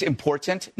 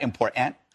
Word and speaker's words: important: By the way, it's important, not important. Okay. Right. If you're gonna school us on education, pronounce the damn T in important.